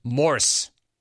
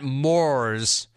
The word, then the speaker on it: Morse: Except it's Morse. Morse. Rhymes with horse. – Laugh out loud. – Not Moors. It's not s'mores code. It's Morse code. I love it when you clones try to get clever. He spelled it M-O-R-E-S.